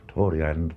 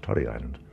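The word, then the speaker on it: Tory Island: Torrey Island, but Francesca Condoon told me years ago it should be pronounced Torrey Island.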